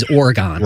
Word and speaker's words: Oregon: It's the exact same word. I just grew up saying that Oregon. Word is Oregon. – Right. Oregon. – We're right. – in Oregon, and that's the proper way to say it.